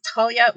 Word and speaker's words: Talia: I will say it's Talia without an H.